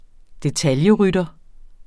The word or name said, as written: detaljerytter